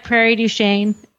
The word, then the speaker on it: Prairie du Chien: Am I saying this right? Prairie du Chien? – you are prairie du chien.